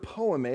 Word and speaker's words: poiema: But I'm only, I'm only kind of riffing off this and making light of it because in reality, the word poiema, if you'll look up on the screen, we've defined it for you. This is how you pronounce it in the Greek.